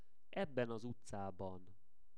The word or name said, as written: ebben az utcaban